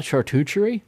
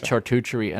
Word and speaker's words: charcuterie: So, Brad Rodriguez is probably hearing you say that word right now and going, "It's not charcuterie." He did that on Made for Profit. He called it charcuterie, and I just cracked up laughing.